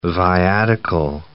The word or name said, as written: viatical settlement